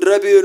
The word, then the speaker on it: rápido: Weón analfabeto, weón rápido no tiene doble R. Es una R que va al principio, por tanto se pronuncia doble R, como roto.